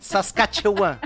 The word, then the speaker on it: Saskatchewan: Saskatchewan, não é? – É, é.